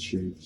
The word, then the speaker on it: Cherif: Pour ceux qui n'aiment pas mettre accent sur le E à la fin. Mais j'aime qu'on m'appelle Sherif, Ça, c'est tout court, Je n'ai pas besoin de te caricasser pour prononcer mon nom.